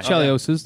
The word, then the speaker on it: Chelios's: I'm saying that wrong. – Anyway, no, Chelios's. Okay. Yeah, that's pronounced. That's correct, yeah.